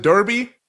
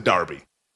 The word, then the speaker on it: derby: We would pronounce it derby. They pronounce it derby. I don't make the rules.